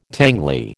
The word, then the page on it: tangly: American English